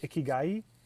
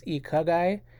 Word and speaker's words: ikigai: The pronunciation is ikigai. I had said it was ikagai, but I don't want to mislead you all. It is ikigai.